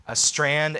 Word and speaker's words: Strachan: Some teachers that I would recommend on this would be Owen Strand. It's his last name is spelled Strachan. That's how you look him up, but it's pronounced Strand. Owen Strand.